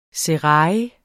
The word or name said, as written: serail